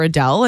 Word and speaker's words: Adele: That's not how you say her name. Adele. Adele? Adele? – Adele.